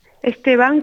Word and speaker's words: Esteban: Bonjour Naïbi Esteban, c'est comme ça qu'on prononce votre nom? – Esteban, comme le prénom.